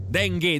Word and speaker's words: dengue: Dengue, dengue. Ma non è vero mai, ragazzi. Dengue, si dice dengue.